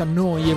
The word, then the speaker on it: Neu: Si scrive Neu, si pronuncia Noi e vuol dire nuova.